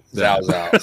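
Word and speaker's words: Cao Cao: No, or the It looks like Cow Cow, but they're pronouncing it Zhao. – Yeah, Zhao Zhao.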